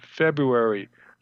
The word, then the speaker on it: February: Another one I wrote that was very popular was about February. I said, "Why do we have the first R in February? No one pronounces it."